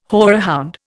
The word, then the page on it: horehound: Indian